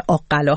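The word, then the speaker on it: آق‌قلا: البته فکر میکنم تلفظ این شهر آقلا هست، از صبح قد من اون رو تلفظ کردم، در واقع یکمی دچار اشتباه شدم.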